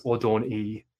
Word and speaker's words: Adonai: But in Hebrew, it says Yahweh said to Adonai, or more correctly pronounced, Adon-I. Adon means Lord and E means mine.